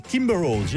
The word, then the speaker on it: Kimberose: Et nous découvrirons le premier album Soul Funk du groupe français Kimberose.